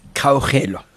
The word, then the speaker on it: Kgaugelo: It's Kgaugelo.